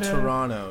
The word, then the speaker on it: Toronto: Toronto. You don't pronounce the last T.